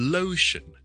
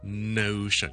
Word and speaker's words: lotion: 将本身系 l m 嘅字咧都会读咗做 n 音，咁咧就会变咗，譬如 lotion l o t i o n 就会读成 notion 咁样。或者名字叫做 Larry L-A-R-R-Y 就可能会最好读成 Nary 咁样，咁反而咧系会变咗一个错嘅发音。